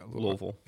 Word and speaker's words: Louisville: Where you're from Louisville, so it's more. – You said it right, yeah. Louisville.